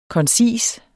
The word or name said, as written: koncis